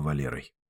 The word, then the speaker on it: Валер: Не только Валерой. Валер с ударением на первом слоге.